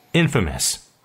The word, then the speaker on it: infamous: 이 발음을 할 때 infamous라고 읽어야 되는 거지 이걸 infamous라고 읽으면 안 돼요. 왜냐하면 강세가 in에 있어요. in에다가 강세를 쳐놓으세요. in에 강세가 있으면 나머지 모음은 다 슈아로 약하게 읽는 거죠.